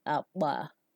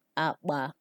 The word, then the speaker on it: akpa: Next, there is KP, which is pronounced B. An example of a word with B is Akpa. Akpa, which means bag in English.